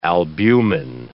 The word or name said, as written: albumin